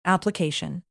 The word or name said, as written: application